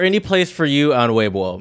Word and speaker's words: Weibo: Another one though that's super popular is called Weibo, which I think is kind of the equivalent of, pronounced, I believe, more like Weibo. – Is it Weibo? Yeah, it's Weibo. You're right.